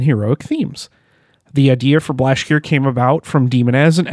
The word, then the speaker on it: Blashyrkh: Most of the lyrics focus on a realm called Blashgir. I don't know how to pronounce it. – You just did. You got it, baby. – I tried. It's spelled Blashyrkh. So Blashgir. – Yeah. Good luck. – A breath at the end.